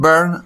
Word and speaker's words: Byrne: el que mejor pronuncia el mejor hispanohablante pronunciando el apellido del dibujante anglo-canadiense es Carlos Pacheco, que aclara una y mil veces que es John Byrne como si fuera quemándose, como la canción conocidísima de, Deep Purple.